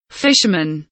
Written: fisherman

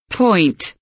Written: point

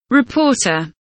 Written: reporter

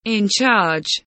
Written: in charge

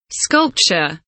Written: sculpture

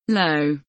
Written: low

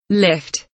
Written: lift

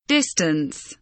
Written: distance

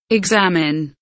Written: examine

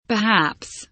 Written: perhaps

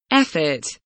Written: effort

